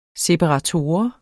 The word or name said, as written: SEPARATORER